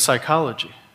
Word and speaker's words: psychology: Why is there a P at the beginning of psychology? We don't pronounce it.